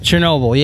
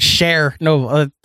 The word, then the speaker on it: Chernobyl: Chernobyl. – Yeah. Did you call it Chernobyl on purpose? Like you phonetically sounded out the word share and then Novel.